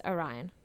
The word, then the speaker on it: Orion: This stars a lot of people making little appearances, but our main characters, if I remember correctly, are John Magaro and Orion Lee, yes. – Oh, apologies, Orion. Like Orion the company.